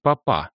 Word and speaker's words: папа: «Я могу попросить папа», — он сделал ударение на последнем слоге.